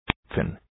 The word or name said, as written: thin